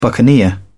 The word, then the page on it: buccaneer: British English